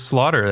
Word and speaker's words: Slaughter: It is Slaughter. That's just how it's pronounced.